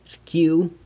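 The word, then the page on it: rcyiu: San Lucas Quiaviní Zapotec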